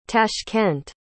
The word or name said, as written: tashkent